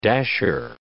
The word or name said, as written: dasher